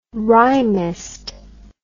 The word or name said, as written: rhymist